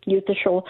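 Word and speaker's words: Eutha Scholl: On Amazon, or just type in my name, Yutha Scholl.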